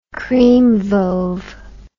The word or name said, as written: creamwove